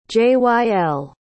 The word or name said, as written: jyl